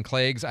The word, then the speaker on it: Klages: And it's going to be from Caroline Klages. I think that's how you say her last name. And if I've butchered it, I'm sorry. It's K-L-A-G-E-S.